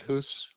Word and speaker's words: Hoos: My name is David Hoos.